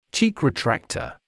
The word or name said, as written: cheek retractor